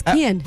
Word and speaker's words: Kian: Say it right, it's Kian.